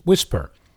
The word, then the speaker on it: WSPR: Last week, I spent a little time talking about the Weak Signal Propagation Reporters Network, or WSPR, pronounced Whisper.